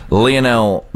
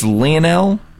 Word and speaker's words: Lionel: Okay, do that pronunciation again for me, because I have heard it about 8 different ways. – I believe it's Lionel. – Okay. – Or you could just go Lionel.